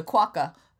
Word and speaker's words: quokka: The quoll? – The quokka.